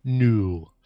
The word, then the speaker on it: Nous: This is a band called Nous, or New, N-O-U-S. So if it's the French pronunciation, probably New.